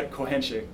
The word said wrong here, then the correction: correnteza: cohenche. – Cohenche. – Correnteza. – Correnteza. – Correnteza. Yes. – Uh, that is uh, how you pronounce uh, undertow which is the the uh, flow of the wave.